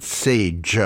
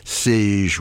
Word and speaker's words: Sage: Nate Smith teaming up with the vocal quartet known as Sage, not Sage like the Spice, but Sage rhyming with beige.